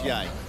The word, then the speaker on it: Ye: Kanye announced on Twitter that he's changing his name to Ye, which is pronounced Ye.